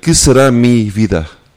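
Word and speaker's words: Qué será mi vida: Not too bad. Que sera mi vida. Which is... In Portuguese, it'll be slightly different. Que sera minha vida.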